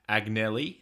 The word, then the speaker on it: Agnelli: Agnelli, is that, that how you pronounce mm-hmm.